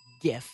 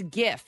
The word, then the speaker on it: GIF: The other one is pronounced with a hard G. GIF. – Sold. Okay. – It's GIF.